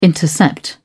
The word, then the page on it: intercept: British English